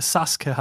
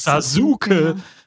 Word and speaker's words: Sasuke: Weißt, sorry, für mich als Naruto-Fan, ich bin schon geschädigt, weil da gibt es auch eine Figur, die Sasuke heisst im Original und in der deutschen Synchro haben sie das 20 Jahre lang als Sasuke. – mit Betonung auf Uke. – Pass mal auf, Sasuke.